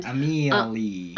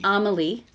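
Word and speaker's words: Amélie: Amelia, but there's no A on the end. – Amelie. – Amelie is how it's pronounced.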